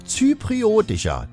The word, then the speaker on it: zypriotischer: Und musikalischerseits der britisch-zyproidische, ich stolper da schon, letzte Stunde bin ich auch schon drüber gestolpert. Wie spricht man das aus? Zypriotischer, das geht doch mit dir. Du musst nur langsam sprechen, siehste.